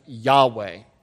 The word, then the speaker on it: Yahweh: Anywhere you see Lord in all caps, that's the proper name of God, which we actually pronounce Yahweh.